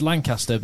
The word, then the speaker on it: Lancaster: It's Lancaster, but that's how they pronounce it over there.